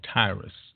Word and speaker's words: Tyre: That was the ancient city called, seems to be spelled T-Y-R-E. Some would call it Tyre. Sometimes it's called Tyrus. But it's pronounced in the Hebrew like Soar.